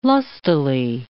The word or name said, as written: lustily